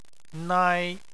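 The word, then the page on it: nai: tone 1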